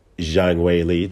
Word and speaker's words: Zhang Weili: Moving on to the co-main event between Zhang Weili, that's the correct way to say it, and Joanna Jędrzejczyk.